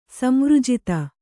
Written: ಸಂವೃಜಿತ

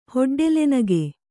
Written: ಹೊಡ್ಡೆಲೆನಗೆ

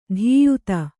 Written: ಧೀಯುತ

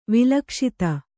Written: ವಿಲಕ್ಷಿತ

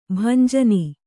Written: ಭಂಜನ

